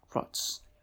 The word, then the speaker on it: Frotz: FROTZ. That's how it's spelled. Frots. FROTZ. Okay.